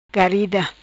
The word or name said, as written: Garida